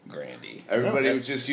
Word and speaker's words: Grande: Grande. – Wow, hey, she Grande. – Said, hey, that's how you pronounce it for real.